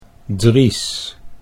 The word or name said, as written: Dries